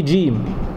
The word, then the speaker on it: جيم: في جيم والجيم دي انت بتنطقها خليط من الدال والجيم، ما بتقولش ايه جيم، بتقول جيم. الناس اللي درست التجويد تعرف ازاي تنطقها. بتجيب بتنطق هذا الحرف وانت لسانك عند مخرج الدال، طرف اللسان مع ايه خلف الثنايا العليا. جيم كانك هتقول جيم.